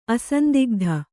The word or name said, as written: ಅಸಂದಿಗ್ಧ